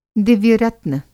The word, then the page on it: दिव्य-रत्न: Hindi